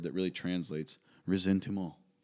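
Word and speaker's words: ressentiment: Mock Shaler wrote a book called, how do you pronounce that in the French? – I don't even know what I'm looking at here. – Top word. – Resentiment. – Okay, resentiment.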